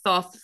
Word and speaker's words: Thoth: Although some people say Thoth, but I think Thoth, from my understanding, is the most correct, even though it is not the ancient Egyptian of that name, which would be Tehuti.